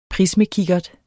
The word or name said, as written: prismekikkert